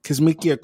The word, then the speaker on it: Kizzmekia: So to start us off in healthcare, I wanted to mention Dr. Kismika Corbett. I think that's how you pronounce her name, Dr. Kismikia, maybe? – Kismikia Corbett.